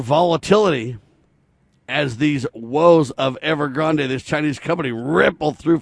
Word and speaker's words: Evergrande: It's a Chinese company, ladies and gentlemen, a huge real estate company that builds real estate, but it's Evergrande, E-V-E-R-G-R-A-N-D-E, so it might be Evergrande. I'm not sure how they pronounce it. It's kind of like Ariana Grande. It's got an E on the end, right? So they call it Evergrande.